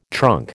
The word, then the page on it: trunk: American English